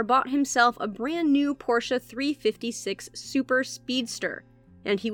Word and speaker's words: Porsche: By the way, if you're wondering why I'm pronouncing it Porsche and not Porsche, it's because Porsche apparently got tired of people saying Porsche, so they made a video telling all of us that we should be pronouncing it Porsche.